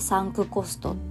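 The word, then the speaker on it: 埋没コスト: そうこの「やめる」という選択っていう書籍の中で「サンクコスト」っていう言葉がよく出てきます。え文字にすると漢字で「埋没」って書いてカタカナで「コスト」それで「サンクコスト」って読むみたいです。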